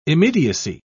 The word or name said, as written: immediacy